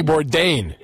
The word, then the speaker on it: Bourdain: I can't pronounce his last name properly. – Right. – Anthony Bourdain, I believe his name was. – Anthony Bourdain. – Yep. Bourdain, okay.